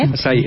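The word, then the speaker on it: açaí: Entonces vas a poner dos tazas de leche de almendra sin azúcar, media taza de acae, asae, achae, o a ver con quién latino cómo se dice. – Asai, es asai. Asai.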